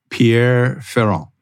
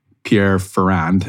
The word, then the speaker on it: Pierre Ferrand: I used Pierre Ferrand, which it looks like Pierre Ferrand. It's Pierre Ferrand. That's how you say it.